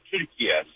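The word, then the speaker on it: Türkiye: The way Turkish people pronounce it is actually the way it said Turkiye.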